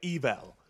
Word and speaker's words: Evel: I'm pronouncing it that way, Evel. – Yeah. – It's E-V-E-L. It's sort of like level without the other L, which I just realized is a palindrome.